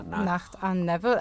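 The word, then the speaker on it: Nacht und Nebel: Nacht and Neville. Actually, that's German.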